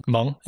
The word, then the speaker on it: Hmong: And these pronounced Hmong. – Hmong? Yeah. Oh, okay. I didn't know that at all.